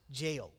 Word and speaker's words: gaol: Jail, if you want to look up Reading Jail, you have to spell jail the British way. It is G-A-O-L, pronounced jail.